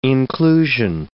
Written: inclusion

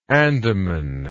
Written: Andaman

